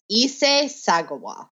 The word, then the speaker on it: Issei Sagawa: Okay, it's Issei Sagawa is this person's name.